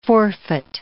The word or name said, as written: forfeit